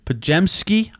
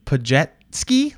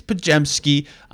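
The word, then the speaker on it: Podziemski: This year, it's Brandon, and look, I'm almost positive his last name is pronounced Pajemski. I've watched enough of his games to get that feel. I've also watched enough of his games to hear announcers pronounce it like Pajetski, like the thing you use on water, and Pachevski, which sounds different. I think it's Pajemski.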